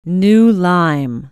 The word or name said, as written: new lyme